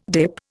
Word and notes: DIP: Indian

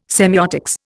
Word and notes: semeiotics: Indian

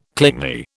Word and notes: clingy: British English

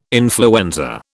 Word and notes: influenza: British English

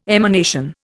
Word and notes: emanation: Indian

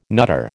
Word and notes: nutter: American English

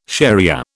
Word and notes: shariah: British English

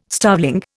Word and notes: starveling: Indian

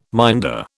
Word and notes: minder: British English

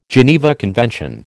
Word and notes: Geneva Convention: American English

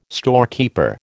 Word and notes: storekeeper: American English

